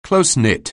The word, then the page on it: close-knit: anglais